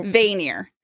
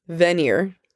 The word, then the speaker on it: Vanir: Not veneers like teeth. – Oh. – Vanir. – I just found my word for the season I'm going to struggle with. Finally have a character name that I can pronounce. – Yay. – Vanir. – Vanir. – Vanir. – At least that's how it's pronounced on the audiobook.